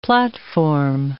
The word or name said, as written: platform